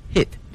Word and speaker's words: hid: 同时的发音呢，从 hide 变成了 hit，直接从开音节变成了闭音节啊。